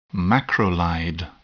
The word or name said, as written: macrolide